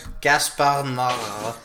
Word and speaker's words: Gaspar Noé: Where we talk about Gaspar Noé and his... – Why did it sound like you were gargling jizz? – That's the correct pronunciation of his name.